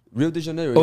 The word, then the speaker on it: Rio de Janeiro: Is that even pronounced correctly? We probably sound like stupid Americans. Yeah. Rio de Janeiro? – Rio de Janeiro. – Okay, yeah, that is correct.